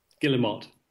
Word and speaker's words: guillemot: And there's an English pronunciation and then there's an American pronunciation. I think it's Guillemot. What, how do you say it? – Guillemot.